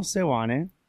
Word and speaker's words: Sewane: No one pronounces it correctly the first time. So Sewane. – Sewane. If you want to like actually pronounce it correctly, so it's imagine all like vowels are just the what is it? It's not. It's with the little squiggly line that just goes upward like a smiley face.